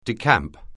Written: decamp

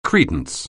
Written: credence

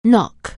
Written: knock

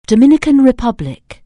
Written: Dominican Republic

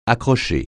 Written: accrocher

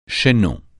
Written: chaînon

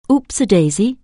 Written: oops-a-daisy

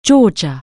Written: Georgia

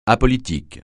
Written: apolitique

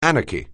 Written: anarchy

